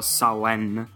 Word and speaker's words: Samhain: La storia è quella di Samhain, o meglio, lo pronuncio come va pronunciato, Samhain. Se c'è qualche persona che parla gaelico magari mi può dare qualche dritta in più su come pronunciarlo meglio.